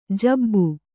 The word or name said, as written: ಜಬ್ಬು